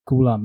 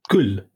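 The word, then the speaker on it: kul (कुल): Like, I know what kula means. In Hindi, it's basically, we pronounce it as kul. – Kul. – Yeah, it's called kul.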